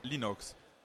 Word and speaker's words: Linux: Ähm, wie sprichst du Linux aus? Linux. Pronouncing Linux as Linux. Genau.